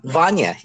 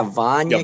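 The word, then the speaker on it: Vania: Is it Vanya King? King? – Oh, Vanya. Yeah. Vanya.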